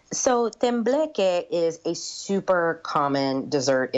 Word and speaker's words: tembleque: I'll take a stab at the pronunciation, tembleque. Am I close? – Perfect. Yeah.